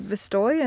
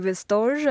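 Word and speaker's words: Vestoj: I pronounce it Vistoy, and so does the founder, but it might be Vistorge, I'm not sure, but it's garments or clothing in Esperanto.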